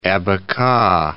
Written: abaca

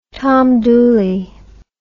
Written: Tom Dooley

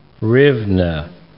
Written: hryvna

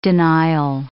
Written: denial

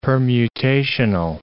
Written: permutational

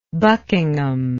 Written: buckingham

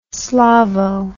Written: slavo

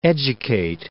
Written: educate

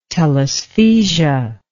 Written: telaesthesia

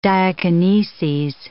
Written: diakineses

